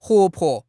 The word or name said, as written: huo2 po5